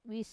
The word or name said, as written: wis